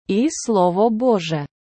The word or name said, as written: і Слово Боже